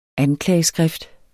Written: anklageskrift

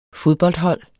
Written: fodboldhold

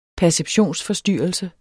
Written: perceptionsforstyrrelse

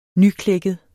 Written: nyklækket

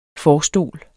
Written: forstol

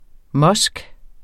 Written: musk

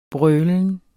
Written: brølen

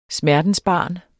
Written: smertensbarn

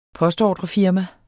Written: postordrefirma

